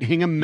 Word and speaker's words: Hingham: A teen driving in Hingham, did I pronounce that right? Hingham, Mass. – Yeah, Hingham, sure.